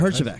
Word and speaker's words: Herjavec: Robert Herjavec? How do you pronounce that? – I think you nailed it. – Herjavec.